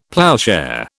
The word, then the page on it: ploughshare: British English